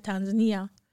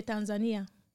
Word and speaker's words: Tanzania: if you're from East Africa, you say Tanzania. – Mm-hmm. – If you're from West Africa, you have a different way of saying it. – How do you say it? – I would say Tanzania.